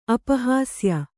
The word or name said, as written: ಅಪಹಾಸ್ಯ